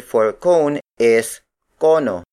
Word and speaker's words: kono: Bola, the Tagalog word for ball, is spelled as B-O-L-A. Again, the Tagalog word for sphere is espera. Up next, we have cono. Cono. Cono. Cono is spelled as K-O-N-O.